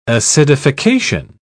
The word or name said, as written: acidification